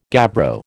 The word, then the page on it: gabbro: American English